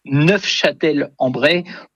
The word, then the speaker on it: Neufchâtel-en-Bray: Et en effet, pour les puristes, dont je ne suis pas, puisque je n'ai pas prononcé le F, mais pour les puristes, en effet, il faut prononcer Neufchâtel-en-Bray pour, en effet, peut-être se différencier de la ville de nos amis suisses.